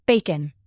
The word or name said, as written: bacon